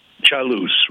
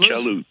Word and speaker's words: Chailus: This is Chailus. Did I say this correctly? – Chailus, right, Chailus. – What's up, man? – Chailus. Italian, remember? – Okay.